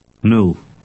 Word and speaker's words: nul: The sound in this word is quite short because it does not stand at the end of a word or syllable. Read 7 aloud. – Nul.